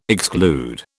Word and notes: exclude: British English

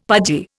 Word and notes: pudgy: Indian

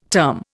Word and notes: term: Indian

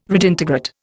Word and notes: redintegrate: Indian

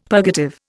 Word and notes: purgative: Indian

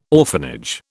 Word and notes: orphanage: British English

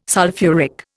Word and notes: sulphuric: Indian

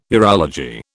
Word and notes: urology: British English

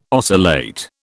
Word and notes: oscillate: British English

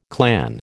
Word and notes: clan: American English

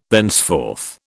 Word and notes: thenceforth: British English

